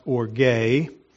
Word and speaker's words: orge: I'll say this, that the Greek word here is pronounced orge.